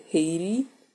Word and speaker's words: Haiti: Yeah, I'm going to say it like an American, because I just, in my mind, I can't say it like a Kenyan. I just can't. It's going to sound funny, so I said Haiti.